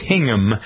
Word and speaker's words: Hingham: Is that how you say it? – I had dinner there a while ago. It's Hingham.